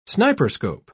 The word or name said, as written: sniperscope